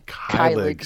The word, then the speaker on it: Keilig: Keilig.